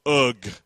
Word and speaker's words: Og: King Og. Turn to your neighbor and say Og. It's actually in the original pronunciation, it's Ug.